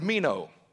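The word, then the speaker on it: meno (μένω): And the Greek word abide is the word meno. It's spelled M-E-N-O.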